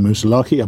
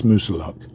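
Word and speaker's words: Moosilauke: I keep getting the name wrong. I keep saying Moosalaki. I believe it's Moosalock.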